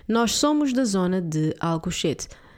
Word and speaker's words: Alcochete: But if you don't, this is how it's spelled. A-L-C-O-C-H-E-T-E. Alco shit. Okay. Oh, goodness, sounds terrible.